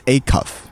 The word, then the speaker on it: Acuff: Acuff? Really? I had no idea it was Acuff. – Acuff. – Right? Acuff, right. So Jon Acuff.